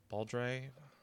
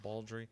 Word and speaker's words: Baldree: How would you say that? Baldry? – Yeah, I guess. – Baldry.